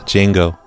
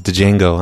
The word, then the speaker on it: Django: I don't think I realize that Django is pronounced Django. I thought it was Django.